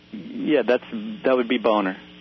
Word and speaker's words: Boehner: Well, Peter, you know, John Boehner, or Bonner, however you pronounce his name. – He actually says Boehner.